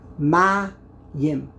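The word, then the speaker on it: Ma-yim (מַיִם): So let's have the word ma'im. Ma-yim. Ma-yim has two syllables. Ma-yim. The second syllable is made up of two letters. Yud-mem.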